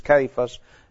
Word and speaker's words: Cephas: You have him as Cephas as we call it. The pronunciation is Cephas.